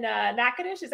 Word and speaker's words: Natchitoches: From the house in Tallahassee, she's moved to the one in Natchitoches.